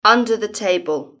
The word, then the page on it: under the table: British English